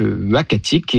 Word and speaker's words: Uhackatic: Alors, le nom, ça sera donc Uhackatic. Et ce nom-là, donc, il s'écrit U-H-A-C-K-A-T-I-C, nous a été proposé par le conseil des Inuits d'Ekunitchit, qu'on a contacté justement pour avoir leur avis.